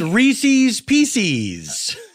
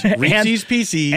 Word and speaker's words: Reese's Pieces: I think it's Reese's Pieces. – I know. – That's how it's pronounced. Reese's Pieces.